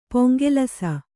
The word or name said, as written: ಪೊಂಗೆಲಸ